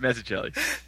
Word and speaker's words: Mazzucchelli: And so that was kind of the gateway onto, I'm going to butcher his name again, David David Mazzucchelli.